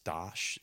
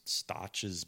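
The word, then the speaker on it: Stauch's: It was across the, what was actually, I believe it's pronounced Stosh. It's S-T-A-U-H or something. Mm-hmm. Stosh's bathhouse.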